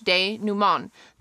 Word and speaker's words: denouement: The English word is pronounced like the French denouement. The last syllable has a nasalized vowel.